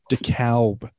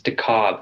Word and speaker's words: DeKalb: I heard on the radio, KFBC, Dave Walsh, I think it's DeKalb. I think the L is silent.